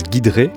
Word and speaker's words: Giedré: son, nom s'écrit Guiedré » mais ça se prononce Guidré.